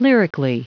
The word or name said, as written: lyrically